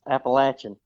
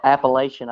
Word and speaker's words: Appalachia: Yes, Appalachian. – Appalachian. – The best way to remember how to say it is if you call it Appalachian, I'll throw an apple at you.